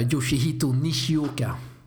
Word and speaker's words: Yoshihito Nishioka: Yoshihito Nishioka.